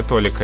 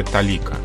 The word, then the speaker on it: толика: Малая толика – это э, ошибка. Правильно говорить малая талика – Вообще это довольно загадочное слово «талика», которое не употребляется в современном языке ни в каких других сочетаниях, кроме угу.